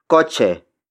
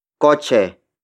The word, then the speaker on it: kotse: The first word is kotse. Kotse. Kotse. Kotse is spelled as K-O-T-S-E.